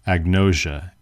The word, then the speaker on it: agnosia: Agnosia, A-G-N-O-S-I-A, agnosia, A-G-N-O-S-I-A.